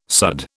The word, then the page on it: sudd: British English